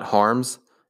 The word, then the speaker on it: Harms: harms and my last name is spelled h-a-r-m-s so yeah um,